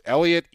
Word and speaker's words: Elliott: My name is spelled Elliot, E-L-L-I-O-T-T.